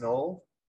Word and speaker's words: Naul: In October 2004, Rachel was found brutally bludgeoned and beaten in the bedroom of her Knoll house in Ireland. It's Knoll, isn't it? Knoll. – What? – Is it pronounced Knoll? – Yeah, N-A-U-L. – Yeah. – Yeah, Knoll.